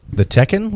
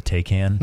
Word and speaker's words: Taycan: Wait, the Tekken, like the video game? – No. I think it's spelled Taycan. Oh, the Taycan. – Not even close.